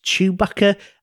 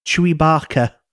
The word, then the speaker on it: Chewbacca: My nephew's obsessed with Star Wars at the moment, but he pronounces Chewbacca as Chewy Barker.